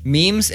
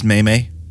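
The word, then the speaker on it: meme: But, like, memes and... Okay, this I'm switching gears here because it got me thinking about... – It's pronounced Maymay. – No, it's not.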